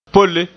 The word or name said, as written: polle